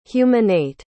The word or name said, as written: humanate